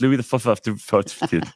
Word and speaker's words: Louis XIV: But it taught me that Louis XIV is actually pronounced Louis the XIV.